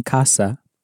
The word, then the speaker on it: casa: It's worth noting at this point that the C in casa is pronounced as a hard C, like the C at the beginning of the English word car.